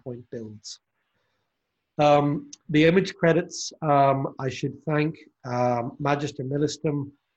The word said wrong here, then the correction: Magister Militum: Magister Militum, my Latin teacher would correct me on my pronunciation,